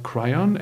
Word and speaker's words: Kryon: Ähm, ich habe auch einige gechannelte Texte gelesen auch einige äh, Texte von äh, der äh, recht bekannten geistigen Wesenheit Kryon oder Kryon, englisch ausgesprochen, schreibt sich mit Y.